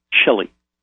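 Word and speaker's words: Chille: Sure, it's C-H-I-L-L-E. – Pronounced? – Chili. – Just like it sounds.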